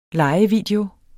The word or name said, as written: lejevideo